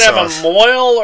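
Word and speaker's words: mohel: They pronounce it mole or mole? – What? – The guy that does the snipping when they get to be teenagers. – Moyle, yeah.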